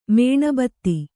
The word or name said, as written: ಮೇಣಬತ್ತಿ